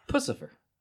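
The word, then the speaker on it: Puscifer: No, it's like Pussy and Lucifer. – Yeah, Pussifer.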